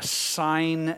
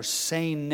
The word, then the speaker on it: seine: Again, Jesus was pointing to the familiar fishermen on the Sea of Galilee would throw those large circular nets, a sein net or seine net, S-E-I-N-E, you pronounce it.